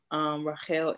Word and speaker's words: Rachel: Rachel Israel. You spell it with the C, R-A-C-H-E-L, Israel on YouTube.